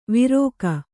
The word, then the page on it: ವಿರೋಕ: Kannada